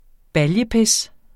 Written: baljepis

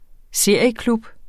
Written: serieklub

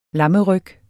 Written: lammeryg

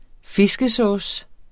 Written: fiskesauce

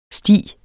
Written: sti

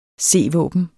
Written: c-våben

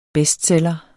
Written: BESTSELLEREN

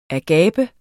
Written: agape

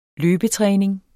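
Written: LØBETRÆNING

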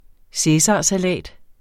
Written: cæsarsalat

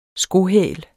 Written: skohæl

